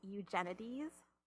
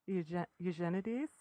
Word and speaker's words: Eugenides: Oh, I pronounce it Eugenides. – So do I. – I do too, but I go a bit more like Eugene. Eugenides.